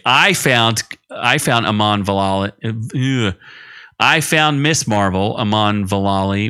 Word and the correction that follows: Vellani: What the hell's her name? I keep mispronouncing it. Iman Vellani.